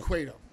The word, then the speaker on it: Cueto: I said, yes, you pronounced the U in Cueto, but not in Quintana, just so you know.